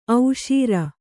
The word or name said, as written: ಓಸರು